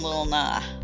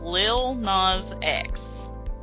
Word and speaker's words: Lil Nas X: For some reason, I don't want to pronounce the S in that. I just want to call him Lil Nah.